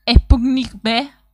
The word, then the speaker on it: Sputnik V: ¿Cómo se pronuncia Sputnik B? Y es Sputnik B, no 5.